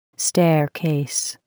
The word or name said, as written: staircase